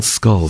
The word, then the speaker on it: Schull: It's pronounced, spelled S-C-H-U-L-L, but it's still called Skull for some reason.